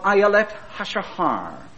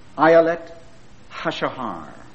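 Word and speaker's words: Ayelet Hashahar (אילת השחר): "Well," he said, "we pronounce it like this, and I think I have it correctly." He said, "we call it Ayelet Hashahar. Ayelet Hashahar."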